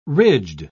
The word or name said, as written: ridged